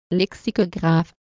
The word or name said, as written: lexicographe